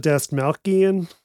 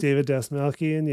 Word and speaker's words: Dastmalchian: David, I never know how to pronounce it. – But Dastmalchian. – I believe that's how his name is pronounced. – David Dastmalchian, yeah.